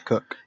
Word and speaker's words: Koch: Therefore, when Sam, I don't know how to say his last name because I've heard it pronounced like three different ways. Koch, Cock, and Cook. It's spelled K-O-C-H. – Cook. – That's Cook.